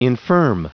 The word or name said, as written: infirm